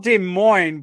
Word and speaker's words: Des Moines: It's called Des Moines, but it's spelled Des.